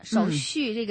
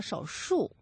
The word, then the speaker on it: 手续: 对了，手续这个词语要注意，嗯，有的时候你会读成了手术。